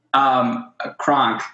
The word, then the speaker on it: Kronk: Kronk, K-R-O-N-K.